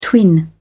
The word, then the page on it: twin: British English